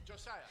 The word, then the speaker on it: Josiah: Josiah.